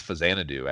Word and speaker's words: Faxanadu: I think it's Faxanadu, actually, is the proper Faxanadu.